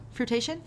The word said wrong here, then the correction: fruition: Fruition. – Fruition? I said fruitation.